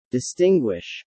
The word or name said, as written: distinguish